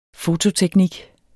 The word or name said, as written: fototeknik